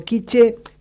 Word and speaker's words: quiché: Pero quiché, con el acento en la E, escrito de la misma manera, es una nación maya.